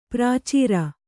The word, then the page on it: ಪ್ರಚರ: kannada